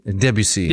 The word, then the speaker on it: Debussy: Composers like Wagner used the narrative foundation of Norse mythology to create an intricate operatic cosmos, whereas Ravel and Debussy, is that right? – Is it Debussy? – Debussy. – Debussy is how you say it? – Sorry.